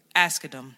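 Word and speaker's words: Asghedom: Ashkedom.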